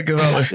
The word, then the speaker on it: Gavala: I hear your last name rhymes with squalor. – Yeah, Gavala.